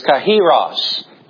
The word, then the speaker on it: kairos: And then the second word is, I might pronounce it a few different ways this morning, and you'll have to forgive me if I say kairos, or the proper pronunciation is kairos.